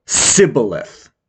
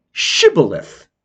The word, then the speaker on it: Shibboleth: On the western side of the Jordan, they would pronounce the word much more hard, Sibboleth. While the people on the eastern side, their dialect would pronounce the word with a more soft beginning, Shibboleth.